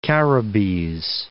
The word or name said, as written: Caribbees